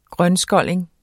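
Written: grønskolling

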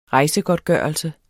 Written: rejsegodtgørelse

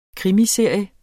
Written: krimiserie

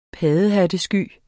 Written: paddehattesky

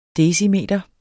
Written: decimeter

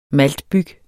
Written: maltbyg

